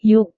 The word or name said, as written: yuk